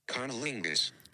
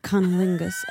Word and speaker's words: cunnilingus: Cunnilingus. – Cunnilingus.